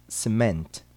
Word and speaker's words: cement: Há, porém, duas palavras cuja sílaba tônica recai no sufixo ment. Ouça e repita. Cement. Lament.